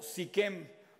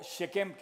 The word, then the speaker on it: Shechem: Este, eh, la SH es una palabra que se pronuncia en hebreo, y por ejemplo, Sikem sería con SH, o Shechem, que es como se debe pronunciar.